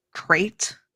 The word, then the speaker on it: Krayt: They are going to be talking about Darth Krayt. I think I pronounced that right. – You did.